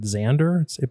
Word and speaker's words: Xandr: So one of the companies, I guess you pronounce it Xandr, but it's spelled X-A-N-D-R.